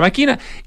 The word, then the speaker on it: Machina: se pronuncia, ¿no? – Maquina, sí. – Maquina, ¿cierto? Se escribe Machina con dos C, pronuncia Maquina, y más recientemente fue pasante de investigación en el Museo de Arte Moderno de Buenos Aires.